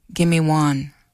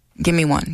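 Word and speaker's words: gimiwan: The Ojibwe word for it's raining is gimme one. Like, gimme one. Gimme one. Easy to remember, right?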